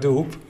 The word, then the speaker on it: dupe: Ja, ja maar zo e. spreek je niet uit. Je spreekt uit doep.